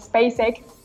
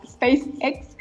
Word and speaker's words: SpaceX: La semana pasada, y concretamente martes y sábado, fuimos testigos del lanzamiento de la misión SpaceX, lo dije mal, SpaceX, en conjunto con la NASA.